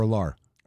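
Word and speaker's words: Allar: For Penn State, you know, Clifford came out and then Drew, is it pronounced Aller or Alar? – Alar.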